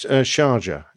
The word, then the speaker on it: Sharjah: is it Sharjah? Sharjah? – It's Sharjah.